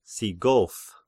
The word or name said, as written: Sigoph